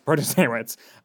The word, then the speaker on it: Protasiewicz: Protasiewicz.